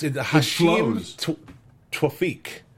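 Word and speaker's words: Hisham Tawfiq: Speaking of names that I can't pronounce, when Dembe, how do you pronounce Dembe's real name? Hakim Flakahoa? – I think that's phonetically correct. – That sounds great. – Yes. – It's Hashim Twafiq.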